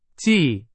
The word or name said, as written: 既